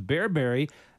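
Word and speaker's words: berbere: It's shakshuka, big, I understand it, in North Africa, featuring a spice that I have known and liked a couple times, bearberry. Tell us about, am I saying that right? – Yeah. – And tell us about it. – Yeah, correct.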